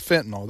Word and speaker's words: fentanyl: But he was talking about fentanyl. That is correct pronunciation of the drug, right? – That's correct.